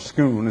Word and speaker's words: Scone: And there was the coronation chair, and there visible right under it was the stone, as they call it, of scone, or scoon as they pronounce it there,